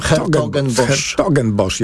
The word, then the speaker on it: Hertogenbosch: No właśnie, jak się wymawia? Zawsze się zastanawiam. – Hertogenbosch. Ja tak zawsze wymawiałem.